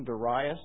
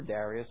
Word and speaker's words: Darius: And it came to pass in the fourth year of King Darius or Darius, which, depending which accent you put on the syllable, that the word of the Lord came unto Zechariah in the fourth day of the ninth month, even in Chislu.